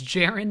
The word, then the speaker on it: Jaron: My name is Jaron May. A lot of people like to pronounce my name Jaron. That's wrong. Just plain wrong. It's Jaron May.